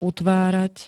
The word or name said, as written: utvárať